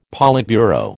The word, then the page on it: politburo: American English